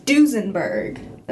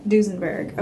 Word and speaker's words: Duesenberg: So for my first car, I picked a Duesenberg. – Love it. – I pronounced that right? – Yeah. – Duesenberg.